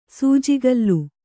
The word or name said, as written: ಸೂಜಿಕಲ್ಲು